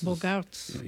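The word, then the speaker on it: Bogaerts: Bogartz written with a double O and G-A-E-R-T-S and A-E in Flemish or Dutch is pronounced A. So, that's... – Bogartz.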